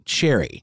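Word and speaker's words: Cherryh: Cherry. There's a seemingly silent H at the end of Cherry, but that's how I'm going to say it.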